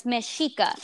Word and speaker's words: Mexica: That's like Mexico, but with an A at the end. And so it's Mexica, the Mexica people.